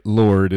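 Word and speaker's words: Lorde: Okay, well, so far Google's saying you're right. Lord is the way you pronounce it.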